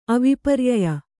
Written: ಅವಿಪರ್ಯಯ

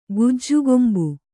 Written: ಗುಜ್ಜುಗೊಂಬೆ

